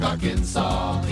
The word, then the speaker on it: Arkansas: and also in Little Rock, Arkansas.